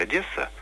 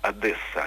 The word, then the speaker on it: Одесса: Тогда ведущие сошлись на том, что есть произношение общелитературно-русское Одесса, и есть произношение близкое, ну, там, к Суржику или, допустим, идущему на Украине разговорному Одесса. Я хотел сделать небольшое дополнение. По-украински произносится без удвоенного «с», просто Одесса.